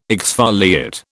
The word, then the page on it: exfoliate: British English